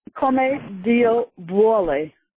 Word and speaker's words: come Dio vuole: It was come Dio vuole.